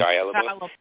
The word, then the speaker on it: Shia LaBeouf: Shia LaBeouf.